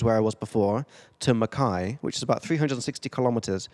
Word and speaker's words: Mackay: I'm in Mackay right now, if I'm pronouncing that right. Have you know Mackay? – Mackay, yep.